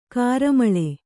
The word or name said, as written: ಘೋರಾಮ್ಲಿ